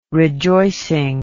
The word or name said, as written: rejoicing